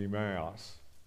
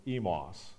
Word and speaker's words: Emmaus: We pronounce it Emmaus. In South Africa, they pronounce it Emos.